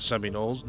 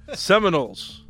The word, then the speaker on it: Seminoles: Because I was looking at them because I know they lead the ACC right now, but the Virginias, they're not the Seminoles. No, that name was just coming to my mind. – He keeps saying it wrong, too. – Seminoles. – Seminoles. – There you go, fella.